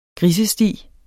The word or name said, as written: grisesti